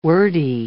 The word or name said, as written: wordy